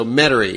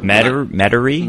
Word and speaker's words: Metairie: I'm from the New Orleans area, so Metairie is like the one big suburb. – Metairie? – Metairie. Some people pronounce it Metairie, but it's Metairie.